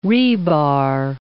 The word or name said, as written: rebar